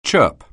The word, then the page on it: chirp: anglais